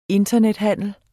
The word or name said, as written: internethandel